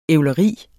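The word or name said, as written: ævleri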